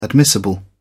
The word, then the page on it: admissible: British English